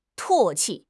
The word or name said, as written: 唾弃